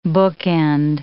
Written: bookend

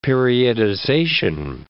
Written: periodization

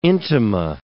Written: intima

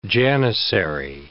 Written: janissary

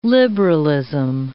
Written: liberalism

